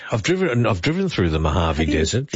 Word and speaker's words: Mojave: Mojave is spelled M-O-H-A-V-E. – Correct. – But the Mojave Desert, I think, is a J, a silent J, as in June and July.